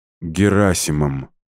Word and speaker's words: Герасимом: «У меня секретный разговор, да, с Херосимом, да?» Она сделала ударение на предпоследнем слоге, отчего имя информатика прозвучало как название японского города. – «Герасимом», — мрачно поправила Лида и скрестила на груди руки.